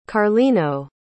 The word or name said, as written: carlino